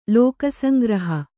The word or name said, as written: ಲೋಕಸಂಗ್ರಹ